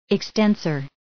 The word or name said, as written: extensor